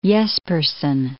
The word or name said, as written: Jespersen